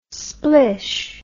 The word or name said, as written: splish